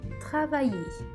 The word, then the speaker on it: travailler: O que vem antes desse E é o som do vai. Travailler. Então, vai, a sílaba forte.